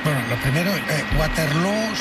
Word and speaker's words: Waterloo: Pues no, desde luego, pero ha hecho mucho daño en la pronunciación de Waterloo, que se pronuncia igual que Feijóo.